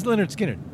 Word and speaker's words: Lynyrd Skynyrd: Well, it's Lynyrd Skynyrd. – Lynyrd Skynyrd. – Why do you say it like that? – Because of all the Y's. That's how it's spelled.